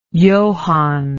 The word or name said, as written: johann